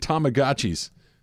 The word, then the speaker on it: Tamagotchi: That and Tamagotchis.